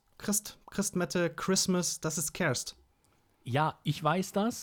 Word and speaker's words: Kerst: Weihnachten auf Niederländisch ist doch Ker Kerst, ne? Kerst. Kerst. – Kerst.